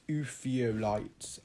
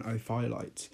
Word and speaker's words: ophiolite: It's an ophiolite, which I used to pronounce ophiolite, but no, it is an ophiolite.